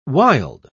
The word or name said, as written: wild